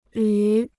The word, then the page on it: 屡: lǚ